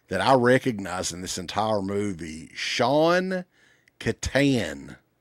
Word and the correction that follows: Kanan: So his dad, Sean Kanan. – Sean Kanan. There you go. – Yeah. – Keenan. – Yeah. I think that's how you pronounce it. I don't know why, because it's not spelled that way.